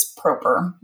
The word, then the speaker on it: Proper: It's actually with a hard O. It's proper, Rachel Proper.